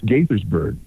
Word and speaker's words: Gaithersburg: It's Gaithersburg.